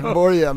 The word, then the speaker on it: Börje: Vi är ju noggranna med uttal, så att Börje, eller hur säger de det? – Ja. Jo, de säger det, Börje.